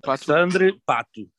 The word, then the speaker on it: Alexandre Pato: Did she say it right? Alexandre Pato. – Alexandre Pato. – Alexandre. – She said it the correct way.